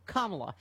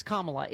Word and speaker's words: Kamala: Look, it's on your T-shirt, Kamala. Yep. Everyone knows it's Kamala. It is not a difficult name to pronounce.